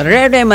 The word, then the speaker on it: Rere: At tama bang bigkas ko dito, Rere? – Tama, kapatid niya si Ruru. – Ah, oo. Okay. Oo, Rere Madrid.